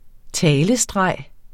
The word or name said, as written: talestreg